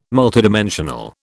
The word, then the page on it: multidimensional: British English